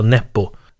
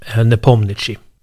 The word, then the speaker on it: Nepomniachtchi: Det är lite svårt att uttala hans namn, ska jag säga det. Jag snubblar lite på det. Om man uttalar så som han skrivs, om man uttalar liksom ordagrant, eller man ska säga, så heter han Jan Nepomniachtchi eller något sånt. Jag har försökt lyssna på hur det låter när ryssar faktiskt uttalar honom. De säger ofta Nepo, men de säger också Nepomniachtchi.